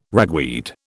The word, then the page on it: ragweed: British English